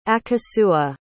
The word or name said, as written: akosua